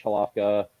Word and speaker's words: Halafka: And he announced on the podcast that I did with Josh Halafka, the chairman of the LPF down here. – Was that how it's pronounced? – Yeah, exactly. Yeah.